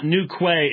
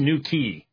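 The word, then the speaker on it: Newquay: But Josh Williamson went from Australia to, I was told that it's not New Quay, it's New Key. Why in the world it's spelled that way? Who knows? It's spelled Q-U-A-Y,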